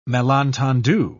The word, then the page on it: malentendu: British English